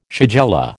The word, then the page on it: shigella: American English